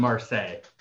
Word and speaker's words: Marseille: And then Marseille? – Olympiacos and Marseille.